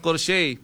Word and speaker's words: crochet: Tama ba yung pronounce ko? Corsier? Hindi corsier. Corsier pala, corsier. So, silent T. Yan ha. Yung kanyang crochet. Crochet. Ah, hindi core pala. Crochet. Sorry lang.